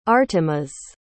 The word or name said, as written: artemas